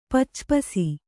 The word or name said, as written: ಪಚ್ಚಪಸಿ